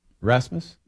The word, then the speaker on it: Rasmus: Rasmus? – Yeah.